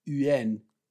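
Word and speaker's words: yuan: το οποίο δεν λέγεται one, λέγεται yen.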